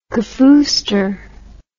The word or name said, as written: kafooster